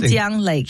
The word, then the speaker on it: Dongjian Lake: I'm probably pronouncing Dongjian Lake. – That sounds much better.